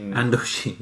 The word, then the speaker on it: Indochine: Andochine, yeah.